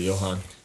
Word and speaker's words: Johan: So he's back on this title pursuit against, is this a soft J? Is it Johan? – I would say Johan.